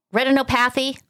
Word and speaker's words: retinopathy: A case of childhood diabetes, which caused kidney failure, heart disease, retinopathy. Did I say that right? – Retinopathy. – Thank you.